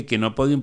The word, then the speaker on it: Chenopodium: Chenopodium se escribe, se lee quenopodium, pero se escribe chenopodium.